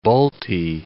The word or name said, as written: Balti